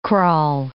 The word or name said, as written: kraal